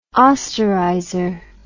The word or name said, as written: osterizer